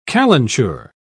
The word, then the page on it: calenture: British English